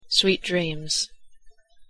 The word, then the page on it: sweet dreams: English